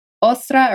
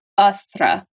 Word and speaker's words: Eostre: so, fun fact a Germanic pronunciation of Eostre is either Ostra or Astra. – Ostra. – But the mm-hmm. Saxon version or the Anglo version is Eostre or Ustra.